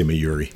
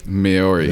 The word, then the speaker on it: Mayuri: Just say Mayuri. – Mayuri.